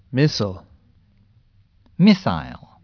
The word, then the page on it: missile: American English; British English